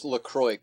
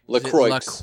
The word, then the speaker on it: LaCroix: I think it's pronounced LaCroix, actually. – LaCroix? – LaCroix.